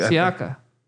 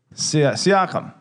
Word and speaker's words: Siakam: Wait, Siaka. – Siakam.